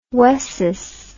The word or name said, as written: wessis